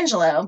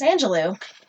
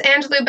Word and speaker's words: Angelou: Maya Angelou, which I always thought was Maya Angelou, but I guess it's Angelou. – It is Angelou, but I've heard it pronounced both ways, so it's kind of like...